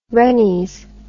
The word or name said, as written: rennies